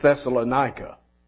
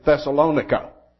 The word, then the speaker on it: Thessalonica: Now, all of my life, I have pronounced it Thessalonica. And every preacher I've ever heard here pronounces it Thessalonica. However, over in Greece, they say Thessalonica.